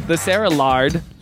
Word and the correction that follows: Laird: Laird? – Laird. – Oh, no. I butchered it.